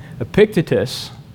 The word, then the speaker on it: Epictetus: Epictetus, and I looked up, that's how you say it.